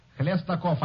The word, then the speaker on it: Khlestakov: Khlestakov, I played it many times when I was a young fella.